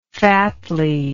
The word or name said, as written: fatly